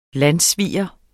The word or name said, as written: landssviger